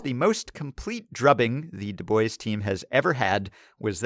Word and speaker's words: Du Bois: And he says it is pronounced Du Bois or Du Bois.